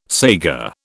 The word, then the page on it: saiga: British English